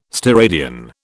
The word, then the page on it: steradian: British English